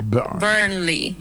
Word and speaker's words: Burnley: Se pronuncia Burnley. La primera parte suena como burn. Con el acento ahí.